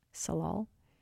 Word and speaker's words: Tsalal: The way that I came across Salal was I was actually no, it's TS. – Ah, okay. – A oh, how do you spell it? TSALAL Salal, and yeah, Gloria, who has this band.